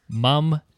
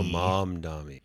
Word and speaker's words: Mamdani: It's Mamdani. – Oh, Mamdani. – You're pronouncing it like the way Cuomo does. Assemblyman Mamdani. Yeah, no, there's an N in the end. – There, that's where the N is. – Yes, Mam, like Mama, and then Dani.